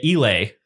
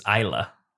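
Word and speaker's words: Islay: But we've been pronouncing it Elay Scotch whiskey, and the correct pronunciation turns out is Isla. – I think you got that backwards, because I generally pronounce it Elay.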